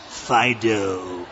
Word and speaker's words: Phydeaux: You know, the doghouse says Fido, but it's spelt in the Euro way, P-H-Y-D-E-A-U-X, Fido.